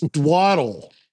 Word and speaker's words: Dowdle: I think it's Dwaddle. – The Waddle. So like Jalen Waddle with a D? – Sort of.